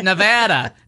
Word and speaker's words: Nevada: The orange thing went to Nevada and gave a really long speech about the correct pronunciation of Nevada and how it was wrong to say the other way because Nevada is right. And as he's saying Nevada, the audience is screaming back at him, Nevada. It's pronounced Nevada.